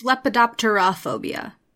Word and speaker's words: lepidopterophobia: That was pretty close. It's lepidopterophobia.